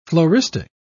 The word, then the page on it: floristic: British English